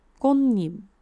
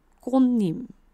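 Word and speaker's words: 꽃잎: 叶是叶子，合起来变成奇怪的发音哦。试试看， 꽃 잎， 꽃 잎， 꽃 잎， 꽃 잎。那你说，哈，说好的 꽃 跟叶呢？ 꽃 连到叶过去的时候，会有一个拟音的鼻音化，所以是 꽃 잎。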